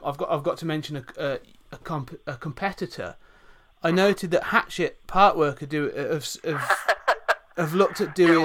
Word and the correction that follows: Hachette: Yeah, well, it's actually pronounced Hachette, but I love the way you pronounce it, Hatchet.